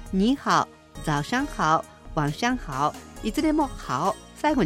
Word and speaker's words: 晚上好: はい、こんばんはは晚上好、晚上好。 – 今度は、晩という字ですね、夜という意味の晩、それに上に好きと書いて晚上好、これがこんばんはですね。